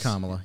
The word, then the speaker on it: Kamala: Kamala. Yeah. I was trying to specifically thinking about how Kamala should be pronounced, and that is accurate, correct? – Mm-hmm.